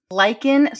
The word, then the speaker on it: lichen: I learned about a medical condition called lichen sclerosis. I hope I'm pronouncing that right. Lichen is L-I-C-H-E-N.